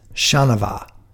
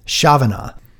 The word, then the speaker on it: Chanavat: I think I've been butchering the winner of yesterday's skate sprint and also the winner of today's team sprint, Lucas Chanavat. And I think I've been mispronouncing it, Chavana.